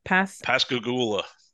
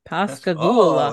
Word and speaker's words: Pascagoula: Pascagoula. – Pascagoula.